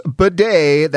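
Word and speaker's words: Badie: Yes, it is Bidet. That is what he's going with.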